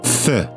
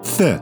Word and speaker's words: fin: The title for today's Green Pastures, well, it's actually The, which is, I think, pretty sure, if I can trust YouTube, how the French pronounce the word that looks like our English word fin, F-I-N. It means finish, end, finale, fe. So there you go. Today's Green Pastures, fe.